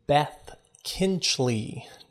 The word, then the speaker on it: Beth Kinchlea: I am joined today with Beth Kinchlea.